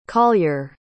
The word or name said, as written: collyer